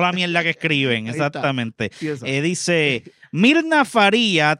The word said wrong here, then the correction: Faria: trabajó por... Bueno, Faria. Mirna Faria. Perdónenme, exacto. Mirna, sí, pues no hay acento en la I.